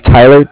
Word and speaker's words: Tyler: Yeah, just put my name is Tyler, T-Y-L-E-R, and I'm calling from Bank of Cardiff. It's spelled C-A-R-D-I-F-F, as in Frank Frank.